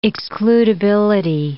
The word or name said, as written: excludability